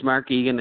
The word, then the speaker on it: Mark Eagan: Yes, it's Mark Eagan.